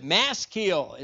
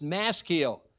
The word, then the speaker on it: Maskil: Maskil is how we would pronounce it, or at least that's how I was told it's pronounced. Maskil.